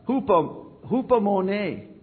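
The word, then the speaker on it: hupomone (ὑπομονή): And hupomone. That is the pronunciation of this particular word.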